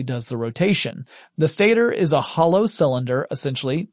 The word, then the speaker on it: stator: You have the stator, or stator if you prefer, but stator is typically how I heard it pronounced.